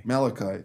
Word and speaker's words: Malachi: Malachi, that's the one.